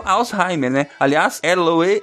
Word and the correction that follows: Alois: É como é que fala isso aí? – Alois.